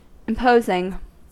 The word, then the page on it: imposing: American English